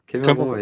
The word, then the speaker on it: Kemerowo: Oder habe ich das richtig ausgesprochen? Kemerovo. – Kemerovo, ja, ja.